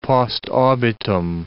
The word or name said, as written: post obitum